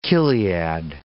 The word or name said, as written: chiliad